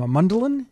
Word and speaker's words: Mundelein: Mundelein.